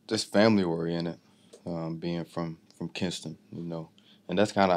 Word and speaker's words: Kinston: Kinston.